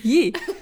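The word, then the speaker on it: Ye: Yee. Yee, also äh, Y-E.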